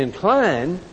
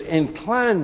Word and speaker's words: incline: When we use the word incline, like I just pronounced it, it is the noun form. But when we say incline, it's the verb form.